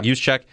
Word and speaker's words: Juszczyk: I don't know how to say his last name. – Juszczyk, yep.